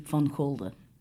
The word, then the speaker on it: Van Gulde: In Dutch, it would be Van Gulde.